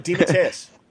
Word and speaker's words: DeMatteis: Yeah, I never know how to pronounce that name. Say it again. – DeMatteis.